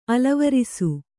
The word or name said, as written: ಅಲವರಿಸು